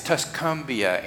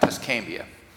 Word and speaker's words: Tuscumbia: Andy can't say it right, but it's Tuscumbia, Andy, not Tuscambia.